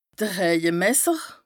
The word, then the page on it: de Rëjemesser: Bas-Rhin; Strasbourg